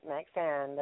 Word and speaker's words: Maxayn: I am pronouncing it right, correct? – That's right, Maxayn. That's correct.